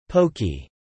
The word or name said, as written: Pokie